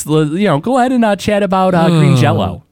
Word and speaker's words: Green Jelly: But then they had to change their name to Green Jelly, but they still pronounced it Green Jello.